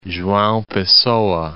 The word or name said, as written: Joao Pessoa